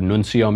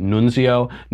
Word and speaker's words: nuncio: That's what the word nuncio means. Some people are pronouncing it nunzio. Nunzio is the name of my Italian uncle who lives in the Bronx. Nuncio is the representative to the United States.